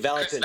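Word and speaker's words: Vallotton: How do you say it? – Thank you, Valentin.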